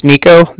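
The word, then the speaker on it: Niko: All right, like I said, my name's Nico. That's N-I-K-O.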